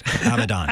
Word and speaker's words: Abaddon: Abaddon.